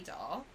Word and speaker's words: Dolle: I'm on Facebook, Twitter, Instagram as Katy Dolle. – Dolle. – Yes, Katydolle. The E is silent, guys, and it's not a stage name.